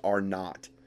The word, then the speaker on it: R-naught: It's R0, but it's pronounced R-naught.